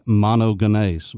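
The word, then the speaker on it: monogenes (μονογενής): Now that is the word in Greek, monogenes. Mono is the word for one as in only. Genes, with a hard G, G-E-N-E-S. If I made that a soft G, you would understand it.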